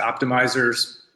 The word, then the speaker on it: Optmyzr: It's Optimizers, spelled, maybe you could spell it. – Yeah, OPTMYZR.